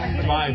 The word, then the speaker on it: Hombre: I think the H is silent. – El Hombre Beer versus Red Horse Beer.